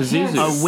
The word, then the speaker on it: Pazuzu: Uh, joins in the unearthing of an ancient tomb where he finds a small carving of Pazazu. I believe oh. that's how you pronounce that. Pazuzu.